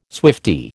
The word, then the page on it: swiftie: American English